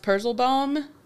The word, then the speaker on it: Purzelbaum: There's a tree in Germany called a tumble tree, and it's pronounced Purzelbaum.